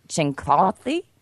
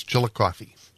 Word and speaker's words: Chillicothe: And I'm not going to pronounce it correctly. Chincothi is what it looks like, but it's some Indian word, so we'll just go with southern Ohio. – I lived in Cleveland for a while. It's Chillicothe.